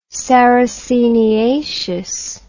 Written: sarraceniaceous

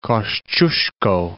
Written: Kosciuszko

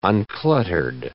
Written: uncluttered

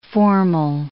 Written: formal

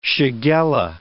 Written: shigella

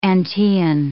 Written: Antaean

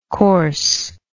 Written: corse

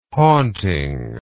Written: haunting

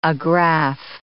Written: agrafe

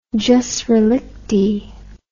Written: jus relictae

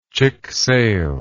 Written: chic sale

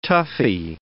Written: toughy

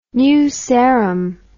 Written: new sarum

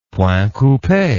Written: point coupe

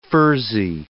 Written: furzy

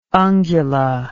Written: ungula

